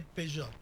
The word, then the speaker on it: Peugeot: Porque no se dice Peugeot, se dice Peugeot.